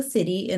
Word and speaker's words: siddhi: And so the next step in that sequence is called the city and it's spelled S-I-D. H-I, is that correct? – Yeah, S-I-D-D-H-I. – Oh, it's D-D-H-I, yes. – Yeah, Sanskrit word.